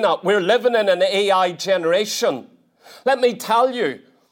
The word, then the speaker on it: Ai: And this little place, this valley of Achor, is only about 10 miles from Jericho, and it's outside a place called Ai. That's the correct pronunciation of it. Not I, it's Ai.